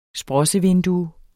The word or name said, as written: sprossevindue